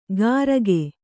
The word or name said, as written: ಕರಕೆ